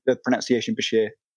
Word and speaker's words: bichir: And then he sort of changed the name, retained the pronunciation bichir, and yeah, it's been spelt B-I-S-H-I-R on occasion. You can go back on a few studies and see that, but it changed the C-H pronunciation.